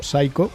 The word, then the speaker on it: Xaiko: Las canciones de Saiko, que se escribe no como sería preceptivo, así con ese toque en inglés, con la P, la S, la Y, C-H-O, Saiko, sino directamente en euskera, X-A-I-K-O.